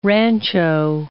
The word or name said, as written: rancho